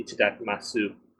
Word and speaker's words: Itadakimasu: I've learned recently that yous are not often pronounced in Japanese words. – Yes, that is true. In fact, the Itadakimasu that we do actually has a U at the end. So if you were to phonetically pronounce it, it would be Itadakimasu.